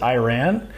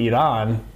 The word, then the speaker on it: Iran: Well, it's funny because a lot of people pronounce it Iran. – Yeah. – When it's pronounced, which sounds like Iran, but then if you pronounce it right, like Iran, it sounds like we ran.